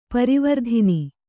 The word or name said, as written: ಪರವರ್ತನ